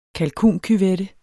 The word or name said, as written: kalkuncuvette